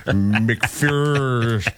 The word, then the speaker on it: McPherson: And he recalled the first time he went to McPherson, Kansas, and pronounced it like most people would. Right. When they look at it, and he got jumped on and verbally abused. And he will never say McPherson. He'll say McPherson correctly for the rest of his life because they shame you immediately.